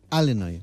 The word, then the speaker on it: Illinois: Sabes que hay, como aquí en España, hay acentos diferentes en Estados Unidos y los nativos del estado de Illinois lo pronuncian Illinois. – Algo que a mí me enseñaron cuando me saqué un título oficial de este idioma es hablar fonéticamente para que no haya ninguna duda. Y si uno busca ese nombre fonéticamente, es Illinois. Así que Illinois sin la S. Así que sin pronunciar la S final, Illinois, una vez más, tu apunte de geografía que nos viene muy bien, ¿eh?